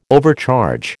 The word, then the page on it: overcharge: American English